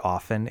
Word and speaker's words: often: For people who don't know, you do not pronounce the T in the word often.